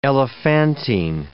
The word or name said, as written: elephantine